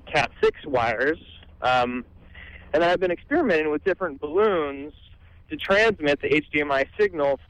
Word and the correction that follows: balun: Just before you get any further, it's pronounced Balun. – Balun. – Balun. It's B-A-L-U-N for balanced, unbalanced.